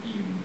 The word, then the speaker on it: Ehud: So, he sends, and I pronounce it Eud. I looked up how to pronounce it, because I always thought it was Eud. There was one that said Ehud, and then one was Eud. And so, I'm going to say Eud, but E-H-U.